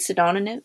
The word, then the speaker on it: pseudonym: Am I saying this right? Pseudonym? – Pseudonym. – Pseudonym, sorry. Pseudonym, yeah.